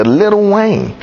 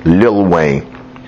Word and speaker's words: Lil Wayne: How do you turn a white middle class guy in the suburbs into Little Wayne? Or Lil Wayne, if you want to be correct in the pronunciation of his name. Lil Wayne.